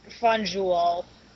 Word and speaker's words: Fanjul: Is it Fanjul or Fonjul? – It's pronounced Fonjul.